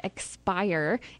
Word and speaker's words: Xpire: So this week I have an iOS app that is called Xpire. It's spelled X-P-I-R-E.